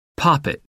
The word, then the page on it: poppet: American English